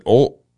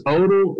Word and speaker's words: Odle: Is it Odell? – It's Odle like Yodel.